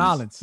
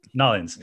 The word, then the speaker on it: New Orleans: How do you pronounce it? New Orleans or New Orleans? – Narlands. – New Orleans. Narlands.